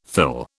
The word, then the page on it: thill: British English